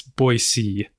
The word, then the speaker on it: Boise: But it's Boise, B-O-I space S-E-E.